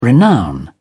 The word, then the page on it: renown: British English